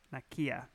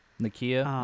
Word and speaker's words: Nakia: Am I saying her name right? Nakia. – Nakia, Where we're not doing anything by force, but we're also not going to do anything.